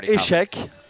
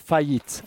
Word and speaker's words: faillite: So you've got échec, uh-huh. an échec, like the chess game, échec, or faillite. More than fracasso, yeah. – Okay, faillite.